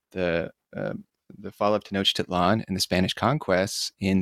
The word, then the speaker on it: Tenochtitlan: Um, before we get started, is Tenochtitlan. That's how we pronounce that, correct? – Well, actually, nobody knows uh, exactly how it was pronounced, so don't worry about it. And uh, uh of course, uh, Tenochtitlan is quite good.